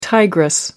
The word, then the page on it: tigress: American English